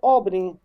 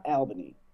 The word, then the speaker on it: Albany: It's Houston, and it's Albany, not Albany.